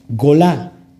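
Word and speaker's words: Golá (גולה): Ojo aquí, exilio en hebreo es gola, se escribe gola. Gimel, Bab, Lamet, Hei, Golá.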